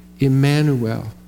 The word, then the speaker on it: Emmanuel: When you say the word Emmanuel, that's kind of how we pronounce it. Here's what the Hebrew sounds like. Emmanuel. Emmanuel. Emmanu with us. Im-man-nu with us. El. God. Emmanuel. God with us.